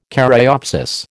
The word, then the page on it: caryopsis: American English